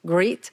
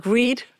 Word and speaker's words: grit: And my son says that I'm saying it wrongly is grit, like G-R-I-T, not greed.